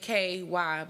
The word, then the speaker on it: KWHY: Really, my DJ name is DJ KY, but K.W.H.Y. Because Kai is KY.